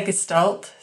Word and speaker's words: Gestalt: I say Gestalt.